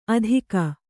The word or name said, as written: ಆದೀಕ